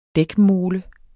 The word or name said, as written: dækmole